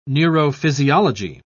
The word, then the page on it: neurophysiology: British English